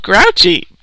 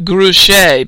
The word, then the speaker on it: Grouchy: Why is he called Grouchy? Because that's his name, said Mrs. Zimmerman. Only it's pronounced Grouché because it's a French name.